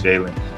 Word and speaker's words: Jalen: You know, when they ask what's your name, my name is Jalen, you know, like Jalen Rose or after Jalen Rose, I've gotten about 50 different spellings of my name.